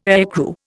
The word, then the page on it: aircrew: Indian